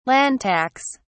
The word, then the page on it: land tax: British English